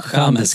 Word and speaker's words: James: James, Ch Chames ska det vara.